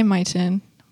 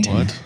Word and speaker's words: Myton: We should talk about the Buffalo Soldiers sometime because they lived in Fort Duchesne, which is by Myton. Oh, I just said it correctly. – Myton.